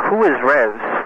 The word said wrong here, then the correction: REVS: What, is that how it's spelled? – R-E-V-S. – R-E-V-S, Revs.